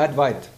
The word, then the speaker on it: Advaita: Ne, Advait. Da ist kein A. A wird nicht ausgesprochen. Ich sitze ja nicht umsonst in Bombay.